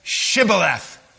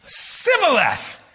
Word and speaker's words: Shibboleth: And when he said, no, they said to him, then say, Shibboleth. Everybody say, Shibboleth, Shibboleth. And then he said, Sibboleth, for he could not pronounce it right.